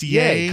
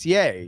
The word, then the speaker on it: Ye: What's so the Y-E is pronounced yay? – Kanye. Kanye. – Oh, is that what it's from? – It's yay.